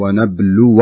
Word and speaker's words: ونبلو: هنا بعض الناس يقرأها ونبلو أخباركم، فلا بد أن ننتبه لمسألة الفتح الواو، فتح الواو فتصبح ونبلو أخباركم وليست ونبلو أخباركم. قد يقول قائل الألف الصغيرة التي بعد الواو مرسوم فوقها حرف مثل الحاء الصغيرة هذه لا تنطق، لا تنطق، تكتب ولكن لا تنطق، ونبلو أخباركم.